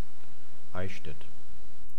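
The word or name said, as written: Eichstädt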